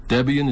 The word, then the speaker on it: Debian: Debian is pronounced Debian, and it derives from the names of Ian Murdoch and his wife Deborah.